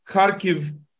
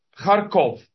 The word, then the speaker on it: Kharkiv: And as, again, purely as a matter of interest, for those people who are wondering whether it is Kharkiv or Kharkov, it's a very, very, very flat vowel, as we English speakers from South Africa. And if you know English speakers from the former Rhodesia, we pronounce our vowels very flatly. And therefore, English writers of these Slavic names, such as Kharkiv, have vacillated between an I and an O. It is the flattest of O's, verging on an I.